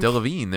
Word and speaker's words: Delevingne: Delevingne, there you go.